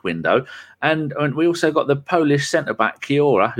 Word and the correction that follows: Kiwior: whose name I say wrong, who played every game for Portugal. No, the other people. Kivior. It's pronounced Kivior.